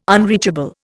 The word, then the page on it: unreachable: Indian